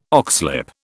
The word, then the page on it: oxlip: British English